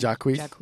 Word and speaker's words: Jacquees: Jacquees.